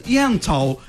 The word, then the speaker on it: Yentl: Ó, a querida Alessandra Maestrini, ela tá em peça, ela tem peça, que é a Ent in Concert. – Yentl. – Eu vou fazer com pronúncia Yentl in Concert.